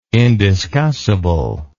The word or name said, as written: indiscussible